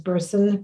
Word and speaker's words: Berson: So my LinkedIn profile is under Hannah, H-A-N-N-A-H. Family name is Berson, B-E-R-S-O-N.